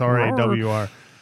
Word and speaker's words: RAR: But I mean, they have shirts and hats that have RAR spelled out phonetically. Yep. So it's it's RAWR. So I feel like that kind of ends that debate, but it still flares up every once in a while.